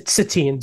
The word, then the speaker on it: Satine: I can't even remember how to say her name. It is Satine.